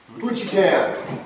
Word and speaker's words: Juchitan: How do you pronounce Hoochie Town? – Hoochie Town. I spell it J-U-C-H-I-T-A-N. Actually, it's Hoochie Town.